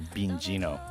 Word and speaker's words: Beenzino: His moniker is a play on Boston rapper Beenzino's name, with Bean replacing Ben, and of course the Z pronounced as a Gino, so Beenzino. There you go.